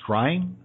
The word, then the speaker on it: scrying: And one of the topics of discussion you've written about extensively is called scrying.